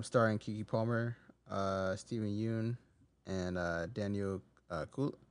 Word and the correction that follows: Kaluuya: I don't know how to pronounce that. Kaluuya. – Oh, Daniel Kaluuya.